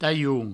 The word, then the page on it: talhon: provençal; taioun